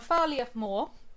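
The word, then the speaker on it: Am Fear Liath Mòr: And Farliath Moor, which is our second candidate for British Bigfoot, which is a Scottish cryptid. – So, and Farliath Moor, am I saying that right? – Yeah.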